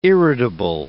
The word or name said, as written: irritable